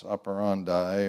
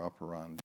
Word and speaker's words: operandi: So his modus operandi, or operandi, if you want to pronounce it more Latin,